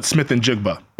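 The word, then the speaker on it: Smith-Njigba: Smith and Jigba.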